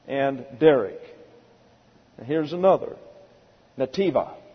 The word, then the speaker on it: netiba: It is the Hebrew nativa. It's spelled N-E-T-I-B-A. Nativa.